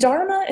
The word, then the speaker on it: Dharma: I have a podcast called Charming Dharma, and Dharma is spelled D-H-A-R-M-A, Dharma. So there's a hidden H in there that you wouldn't expect.